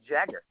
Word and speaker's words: Jagger: My name is Jagger.